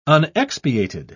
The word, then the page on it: unexpiated: British English